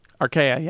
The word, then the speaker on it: archaea: archaea? – Archaea, yeah.